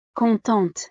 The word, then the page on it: contente: frances